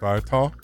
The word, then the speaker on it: Ital: Vinyl is Vital. Rhymes with Ital.